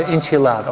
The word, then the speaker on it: enchilada: Dios es más importante que la pechanga, la fiesta, la cerveza, los burritos, las enchifladas. ¿Sí? Oh, siempre lo digo mal. Enchiflada, enchilada.